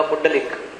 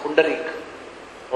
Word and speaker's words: पुंडरीक: पुंडरीक यानी उसका नाम था पुंडरीक, तो जिसको र उच्चारण करने को नहीं आता है फिर वो बोलने लगा पुंडलिक, लेकिन उसका नाम है पुंडरीक।